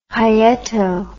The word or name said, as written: hyeto